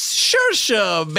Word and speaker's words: Saoirse: And when they say, so rise, Ronan, I say, whoa now, I'll start moaning if you don't pronounce it right. It's Saoirse, baby, say it all night. – Saoirse, Saoirse, say it all night. Saoirse, Saoirse, say it right.